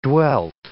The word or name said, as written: dwelled